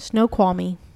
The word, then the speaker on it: Snoqualmie: Snoqualmie.